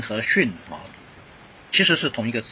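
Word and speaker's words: xun (שׁ): 那钻石跑到右边去了啊，它读 xun 啊，训练的 xun 啊，xun 啊，xun 啊，xun。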